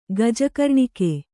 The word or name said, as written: ಗಜಕರ್ಣಿಕೆ